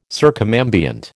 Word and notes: circumambient: American English

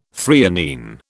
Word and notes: threonine: British English

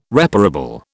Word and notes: reparable: British English

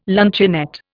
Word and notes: luncheonette: Indian

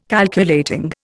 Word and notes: Calculating: Indian

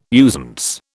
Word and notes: usance: British English

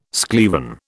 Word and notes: schlieren: British English

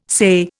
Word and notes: SAYE: Indian

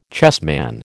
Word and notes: chessman: American English

